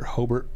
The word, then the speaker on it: Hobart: So I think the cities there are Hobart. Or Hobart, the locals say, Terre Haute, and Richmond, Indiana, on the 4th, 5th, and 6th of February.